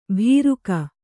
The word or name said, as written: ಭೀರುಕ